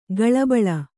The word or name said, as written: ಕುಳಿಬೀಳು